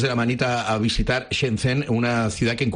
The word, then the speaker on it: Shenzhen: Shenzhen, o sea, Shenzhen.